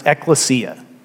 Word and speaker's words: ekklesia: He uses actually a term that in Greek is pronounced ekklesia.